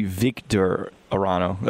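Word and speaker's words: Víctor Arano: I think it's actually Victor Arano. At least according to his pronunciation guide, it's V-E-E-K. Victor.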